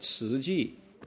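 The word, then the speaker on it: 慈濟: 慈济. How do you spell it? T-Z-U-C-H-I. Nobody looks at T-Z-U and goes, 慈, right?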